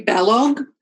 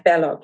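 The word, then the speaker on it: Balogh: Mary Fallop, what period is that set in? – Mary Balog. Sorry, I didn't say it right. B-O-L-B-A.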